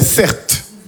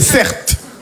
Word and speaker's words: Certe: Je m'appelle Certe. Mon prénom, c'est Certe. Ça s'écrit C-E-R-T-E.